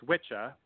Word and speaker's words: Wicca: So in Old English, two C's would make the ch sound and not the hard K. So it would have been pronounced Witcha, which makes sense because it's Old English to Modern English.